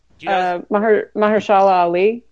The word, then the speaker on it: Mahershala Ali: And I do want to say, do you know how to say the lead actor's name in that film? I can't pronounce it. Mahershala? – Mahershala Ali.